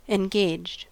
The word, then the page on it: engaged: American English